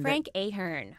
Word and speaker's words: Ahern: Talk to me about, is it Frank Ahern? Ahern? How do you pronounce his name? – Frank Ahern.